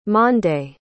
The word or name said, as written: Mande